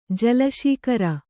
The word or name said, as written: ಜಲಶೀಕರ